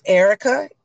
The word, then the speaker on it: Erica: It's Erica, ERICA, Crenshaw, CRENSHAW.